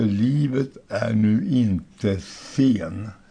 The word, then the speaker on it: Livet är nu, inte sen: Och melodin heter Livet är nu inte sen. Eller sen. – Livet är nu, inte sen. – Livet är nu, ja, inte sen. Så ska det vara. Betoningen där.